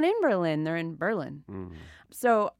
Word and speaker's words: Berlin: while it is spelled like the German capital, it's not pronounced Berlin. It's pronounced Berlin. You know, emphasis on the first syllable. It's very, very subtle. Berlin, not Berlin.